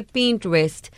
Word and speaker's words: Pinterest: Hay Pinterest, ¿se dice así? – Pinterest.